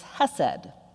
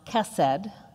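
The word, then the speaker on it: hesed (חסד): The Hebrew word, which means loving kindness, is hesed. It's also sometimes pronounced kesed, but for our purposes, I'll refer to it as hesed today.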